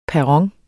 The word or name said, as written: PERRONERNES